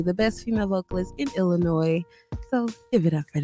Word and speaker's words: Illinois: Illinois, because we don't pronounce that S.